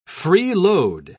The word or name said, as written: freeload